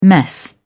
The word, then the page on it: mess: British English